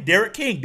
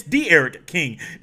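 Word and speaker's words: D'Eriq: his name Derek King. There are different ways to spell a name that pronounces one way. No, it's not Derek King, it's D-Eric King.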